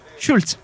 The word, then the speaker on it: Schulz: Questo, come, si pronuncia il, co- eh, come si pronuncia il cognome? Schulz. Robin Schulz, Rockstar Baby.